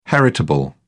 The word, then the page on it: heritable: British English